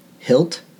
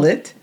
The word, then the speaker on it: HIIT: Do you hilt? Is that how we pronounce it? – No. – Hillit? – Hillit? It's hillit.